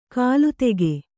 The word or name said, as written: ಕಲಾದಕ